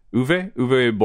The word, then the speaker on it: Uwe: Uwe Boll was like cranking them out, and they were bad.